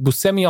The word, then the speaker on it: Buscemi: Er war nämlich bei einer Show zu Gast und der Moderator so, Sie werden gemerkt haben, ich habe ihn Buscemi ausgesprochen. Und so habe ich das getan, weil er das selbst tut. Aber alle anderen sagen Buscemi.